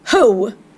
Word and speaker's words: Hough: Men det uttalas Julianne H-O-U-G-H. – Mm, ja, mm. Ho.